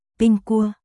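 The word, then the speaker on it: ping cua (苹果): Là đêm bình an, trong tiếng Trung, quả táo được phát âm là ping cua.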